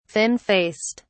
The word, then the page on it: thin faced: British English